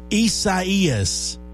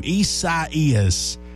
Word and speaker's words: Isaias: Hurricane Isaias is how you say the name. Isaias.